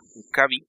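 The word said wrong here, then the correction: W'Kabi: Utilizas una vocal que te falla fonéticamente, pero es que tú lo pronunciaste como si fuera una M y no una W.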